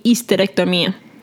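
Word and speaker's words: histerectomía: Now, it seems complicated to say, but you're going to take out the H up front. Like we said, we don't pronounce the H up front. And then, like your favorite rule, the Y at the end, you're converting to an I and A, right? Okay. So it'll be hysterectomia.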